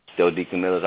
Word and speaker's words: Joe DeCamillis: Yes, Joe DeCamillis.